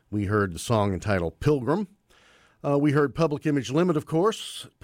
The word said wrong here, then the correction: Public Image Limited: Public Image Limited, of course. I lost a syllable there somewhere.